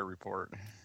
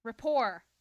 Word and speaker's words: Report: Don't have anything on The Colbert Report. – Rapport. Say it right.